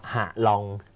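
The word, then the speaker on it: Hạ Long: เป็นภาษาเขาใช่คำว่าวิงแปลว่าอ่าวฮาลองเขาก็ไม่ออกเสียงว่าฮาลองเขาออกเสียงว่าหะลอง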